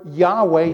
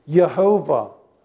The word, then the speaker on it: Yahweh: If you've got a good English Bible, every time you see that capital letters, L-O-R-D in capital letters, that means Y-H-W-H, or Yahweh. See the Y, the H, the W, Y-H-W-H. That's God's personal name. Or another way to pronounce it is Yehovah, Y-H-W-H.